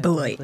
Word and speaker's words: Beloit: She has also worked for Youth for Understanding USA, Beloit College. Did I pronounce that Beloit. – Correctly? Beloit. I'm so sorry.